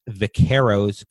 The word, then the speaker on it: Vaqueros: And we'll get a little preview of that on February 23rd when the Vaqueros go to Las Cruces. Did I pronounce that right? Vaqueros? – Yeah.